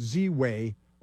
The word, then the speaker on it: Ziwe: and the name is Z-I-W-E. But you have to pronounce it Z-Way or you're a racist, right?